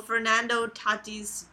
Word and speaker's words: Fernando Tatis: Because, okay, so Fernando Tatis Jr. What a great guy.